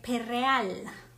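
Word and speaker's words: Perréal: Entonces, el eh, Perreal, con acento en la E, Jim Perreal, que él se encontraba en Milán.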